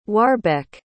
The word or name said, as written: warbeck